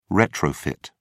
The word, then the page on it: retrofit: British English